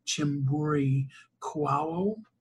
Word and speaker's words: Chihombori-Quao: I do really strongly encourage people to get Africa 101, the wake-up call by Her Excellency Ambassador Arikana Chihombori-Quao. Did I say that right this time? – Yes, you got it right this time.